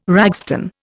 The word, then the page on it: ragstone: Indian